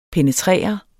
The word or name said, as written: PENETRERET